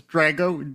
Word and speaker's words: Drago: Drago, I said it right because we discussed the pronunciation of it. It's Drago, D-R-A-G-O.